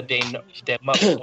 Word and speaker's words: denouement: and then there's the denouement. Well, how do you say that, Mark? Say it denouement.